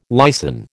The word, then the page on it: lysin: American English